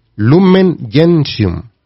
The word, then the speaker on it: Lumen Gentium: Lumen Gentium. Así se pronuncia. Así se pronuncia. Es latín.